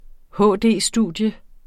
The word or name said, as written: HD-studium